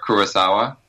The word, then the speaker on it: Kurosawa: So like we say Kurosawa, like Akira Kurosawa. – Right. – But they say Kurosawa, like it's just flat, like it's Ku and then Rosawa, like, and that's really hard.